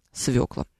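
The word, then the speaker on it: свёкла: В слове свекла буква Ё есть, и ударение там однозначное на свекла.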